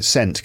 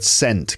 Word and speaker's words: scent: Because it's S-C-E-N-T. Now, if the S is silent, if it's just C-E-N-T, then that's pronounced cent as well, like in money, right? In America, you've got dollars and cents, 50 cents, right? But if it's S-E-N-T, if the C is silent, it's still sent, isn't it?